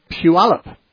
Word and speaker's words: Puyallup: Let's talk to Jacob from Puyallup.